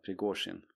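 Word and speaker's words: Prigozjin (Пригожин): Prigozjin.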